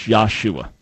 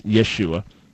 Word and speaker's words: Yeshua: Because some people think that was the proper pronunciation of Jesus' name in Hebrew, whereas it's a non-existent name. It's never existed in the Hebrew language. It doesn't exist. And the proper pronunciation of his Hebrew name was Yeshua.